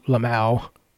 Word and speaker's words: LMAO: And the guy's like, oh, I was just seeing if I get a, you know, to trigger you, you know, Lamau. – Lamau. – That's how LMAO. – No. – But that's how they pronounce it now. That's how the kids say it.